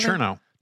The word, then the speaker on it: Chernow: is that how you say his name? – Chernow. – Chernow? – Yeah.